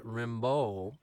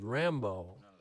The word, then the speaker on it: Rimbaud: And I was, as a part of a classroom assignment, reading some poems by the French poet Rimbaud, whose name is sometimes mispronounced as Rambo.